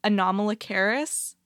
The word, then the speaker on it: Anomalocaris: It's Anomalocaris. – Anomalocaris. – Like it's very phonetic. Yeah. – Like anomaly. – Yeah, like an anomaly.